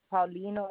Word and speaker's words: Paulino: Karen, and my last name is Paulino. It's spelled Paul and then I-N-O.